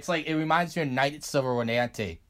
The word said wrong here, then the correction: Rodanthe: Nights of Rodanthe.